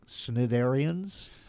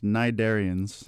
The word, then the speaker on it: Cnidarians: Because your album is called Return of the Snidarians? How do you say that? – It's pronounced Nidarians. – The C is silent, I see.